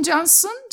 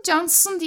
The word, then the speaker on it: Cansın: Ben Türk'üm, ismim Cansın, Cansın diye telaffuz ediliyor dedim.